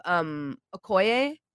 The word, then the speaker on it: Okoye: We also have Okoye, right? – Yeah.